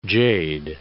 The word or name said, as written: jade